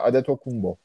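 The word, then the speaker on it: Antetokounmpo: Bunu Latin, Yunan alfabesinde D harfi olmadığı için NT yan yana gelince D oluyor. MP yan yana gelince de B okunuyor. Öyle tercih ediliyor. O yüzden Adetokunbo doğrusu.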